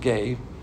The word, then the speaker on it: ge: The word here is ge, it's pronounced ge, spelled G-E, and that means the physical earth.